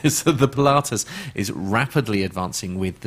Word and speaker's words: Pilatus: Pilatus. – Pilates. Is it Pilates? No. No, it's Pilatus. Pilatus.